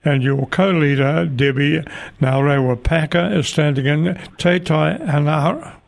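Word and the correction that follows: Te Tai Hauāuru: Whoops, I haven't pronounced that correctly. You might help me there. – Te Tai Hauaruru.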